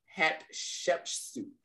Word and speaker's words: Hatshepsut: Okay, it's Hatshepsut. Have you heard this name before? – Hatshepsut, I believe from Egypt? – Yes.